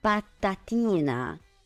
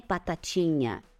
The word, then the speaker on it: patatina: La T, sì, in italiano è sempre T, patatina, patatina, sì, non è patacina, patacina, no, è patatina, patatina, ok?